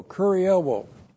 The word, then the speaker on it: kurioō: And then ha namas is the Mosaic Law, and then the verb kurioō. Looks like that in the Greek, K-U-R-I-E-U, long O, kurioō.